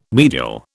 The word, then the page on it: medial: British English